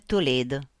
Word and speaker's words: Toledo: Nissim Toledo, mia nonna lo pronunciò alla francese, Toledo.